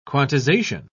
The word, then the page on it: quantization: British English